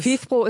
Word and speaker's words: FIFPro: Wie spricht sich das aus? – FIFPro ist komplett richtig.